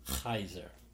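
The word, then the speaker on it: Caesar: And that is how you pronounce it in Latin. It's a hard K sound, Kaiser.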